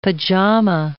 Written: pajama

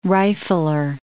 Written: rifler

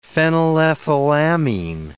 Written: phenylethylamine

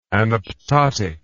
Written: anaptotic

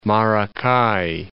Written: Maracay

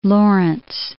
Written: Lawrence

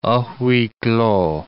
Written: a huis clos

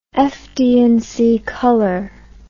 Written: FD and C color

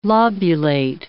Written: lobulate

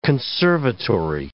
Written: conservatory

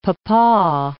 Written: pawpaw

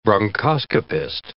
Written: bronchoscopist

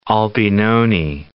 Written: Albinoni

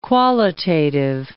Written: qualitative

